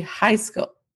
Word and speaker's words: Heiskell: Pronounced high school, but it's not high school. I'm saying it and I'm like, I'm not saying high school. I'm saying H-E-I-S-K-E-L-L.